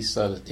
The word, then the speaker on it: Sudduth: Suddeth, L-U-E, initial C, and S-U-D-D-U-T-H.